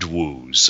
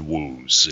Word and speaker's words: wooze: I can't remember who wrote it, but it's word woos. It's how do you spell that woos? – W-O-O-Z-E. – Yeah, that sounds. It sounds like wound and oozing.